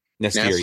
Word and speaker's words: N'astirh: what is it Nastair? Is that how you say it? N'astirh, yeah. N'astirh like nasty.